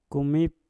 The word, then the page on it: kumik: Mpur